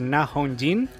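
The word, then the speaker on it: Na Hong-jin: Vamos a hablar de la coreana El Extraño, dirigida por Na Hong Jin.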